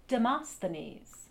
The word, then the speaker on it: Demosthenes: Demosthenes.